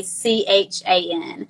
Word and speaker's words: Strachan: I think it's Owen Strahan. It's Strachan.